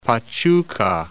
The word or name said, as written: Pachuca